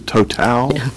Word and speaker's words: Total: Is that how it's pronounced? Is Total? – Yes, sir.